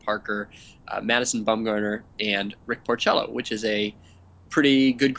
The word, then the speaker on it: Porcello: Yeah, we Porcello. – Yes, Doug Thorburn clarified for us from the Bill James Handbook, which apparently has a pronunciation guide, that it is Porcello.